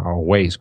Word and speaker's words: Waze: suelo usar eh, Waze, o sea, Waze, como, bueno, se escribe W-A-Z-E.